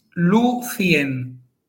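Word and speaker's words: Lúthien: Por eso, lucien son tres sílabas. – Vale. – Luego, normalmente, diremos lucien, porque, bueno, pues al final también simplificamos a la hora de hablar, ¿no? Pero técnicamente es lucien. O sea, que es trisílaba y esdrújula.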